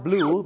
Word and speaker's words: blew: ถ้า blue b l e w ออกเสียงเหมือนสีฟ้าหรือน้ำเงิน